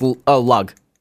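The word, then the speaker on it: Lugh: Yeah, Lu from Guitar Hero 3, the same one. – No, it's pronounced lug. It's L-U-G-H, but it's pronounced lug.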